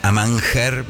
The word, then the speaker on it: Amanger: Los podés seguir en Amanger, así Amanger, vamos a pronunciarlo radialmente, -Palermo.